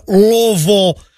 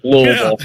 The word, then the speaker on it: Louisville: If you're from here, though, you pretty much drop the I and the S part of it, though, in the Louis. So it's like Louisville.